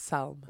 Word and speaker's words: Psalm: Ah, ça se prononce Psalm.